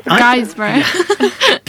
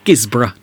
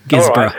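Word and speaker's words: Guisborough: Guysborough. – Bless. He could almost be American, couldn't he, really? Yes. Gisborough, dear boy. Gisborough.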